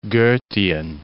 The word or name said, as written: Goethean